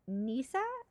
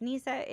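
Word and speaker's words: nisse: Nisa or Tom T, I believe is the pronunciation. I tried to write it out phonetically. It's known as Nisa in Norwegian and Danish and Tomti in Swedish and Finnish.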